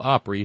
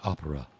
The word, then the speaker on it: Opry: I knew a woman that would, instead of saying Grand Old Opry, she would say Grand Old Opera. Grand Old Opera. Pronouncing distinctly each letter in grand and old and opera, as opposed to Grand Old Opry, which is what it is.